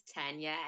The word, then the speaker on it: Tanya: Okay, so my name is Tanya, as you've heard, and thank you for pronouncing my name correctly.